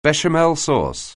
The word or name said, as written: béchamel sauce